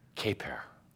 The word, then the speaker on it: Kyeper: K-Y-E-P-E-R. – K-Y-E-P-E-R. P or T? – K-Y-E-P-E-R. Kaper.